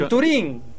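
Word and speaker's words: Venturín: Venturin, con acento en la I.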